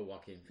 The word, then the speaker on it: Joaquin: Oh, Joaquin. – Joaquin Phoenix. Thank you. Mm-hmm. I always want to say Jack. I always want to pronounce that J.